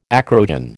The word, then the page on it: acrogen: American English